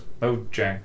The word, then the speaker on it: Mojang: No, it's Mojang.